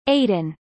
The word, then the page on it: aiden: American English